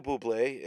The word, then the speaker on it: Bublé: You know, is it Michael Bublé? Is that how you pronounce his name correctly? – Yes.